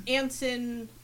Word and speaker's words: Anson: And then I recognized Anson that rhymes with Manson.